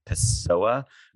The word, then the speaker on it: Pessoa: So she is a Brooklynite who wrote her comparative literature PhD dissertation on the poet Fernando Pessoa, who was so intimidating to me. I don't even know if I said his name right. Is that how you say it? – Perfectly.